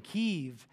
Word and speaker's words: Kyiv: Kyiv, or Kyiv, if you want to get technical, is the Ukrainian way to say it. So when you hear them saying Kyiv, it's out of respect for the Ukrainian people, saying this is the way you would pronounce it.